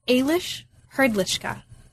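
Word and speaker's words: Aleš Hrdlička: And luckily, David has told me how to pronounce his name, and I'm going to do that right now. This is, wait for it, Eilish Herdlicka, right? – Excellent.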